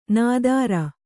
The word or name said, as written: ನಾದಾರ